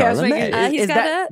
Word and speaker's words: Chalamet: Chalamet.